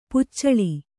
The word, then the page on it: ಪೊಚ್ಚಳ: Kannada; poccaḷa